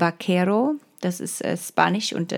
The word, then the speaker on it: Vaquero: beziehungsweise da ist es Vaquero, also mit, mit V geschrieben, aber ich glaube, das V im Spanischen mit, wird wie B ausgesprochen.